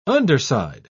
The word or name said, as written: underside